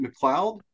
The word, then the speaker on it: McLeod: Rich Buckler, Bob, is it McLeod? Is that how you pronounce his last name? Yeah.